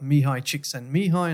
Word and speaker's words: Mihaly Csikszentmihalyi: We looked at various aspects of flow and and of course I'm in Hungary, so we've got Mihaly Csikszentmihalyi, and I hope I pronounced that correctly.